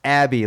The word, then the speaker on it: Abbey: Josh Abbey, pronounced Abbey like a church.